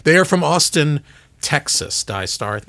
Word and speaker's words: DAIISTAR: Die Star, which is, by the way, is spelled DAIISTAR, because if you don't have a clever spelling, it won't look cool on the display on your Tesla, right?